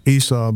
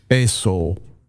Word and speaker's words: Esau: And in Hebrew, his name is pronounced Esau. Esau. Never hear a Jew talking about Esau. It's always Esau.